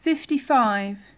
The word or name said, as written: fifty-five